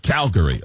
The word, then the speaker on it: Calgary: Calgary. – Okay. Because I've heard it as Calgary.